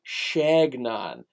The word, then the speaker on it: Chagnon: But Dirty Verdi Chagnon and I, he corrected me because his last name is spelled C-H. Chuh, that's right. That's what the sound is. That CH makes. Chuh. Apparently, it's Shagnon with the SH sound.